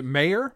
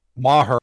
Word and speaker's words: Maher: So Brett, is it how does he pronounce? Is it Mayer? Ma Mahar? Mahar? – Maher. Brett Maher.